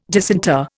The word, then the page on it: disinter: Indian